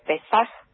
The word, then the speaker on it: Pesaj: ¿Feliz Pesaj? ¿Se dice así, está bien? – Sí, se dice correctamente. Pesaj, kasher besameaj.